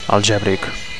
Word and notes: algebric: Catalan

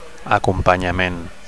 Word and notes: acompanyament: Catalan

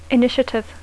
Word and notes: initiative: English

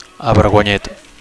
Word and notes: avergonyit: Catalan

